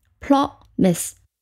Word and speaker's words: promise: 和那个 ox 的 o 发音是一样的，o promise 后面呢虽然有一个不发音的 e，但是 i 发成 i 而不是发成 i，所以它的拼写是 p r o m i s e，读音是 promise。